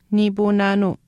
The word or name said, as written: niipuunaanuu